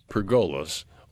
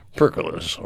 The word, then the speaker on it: pergola: But we're going to talk first about pergolas, or some people say percolas, or whatever they. Pergola is how you say it.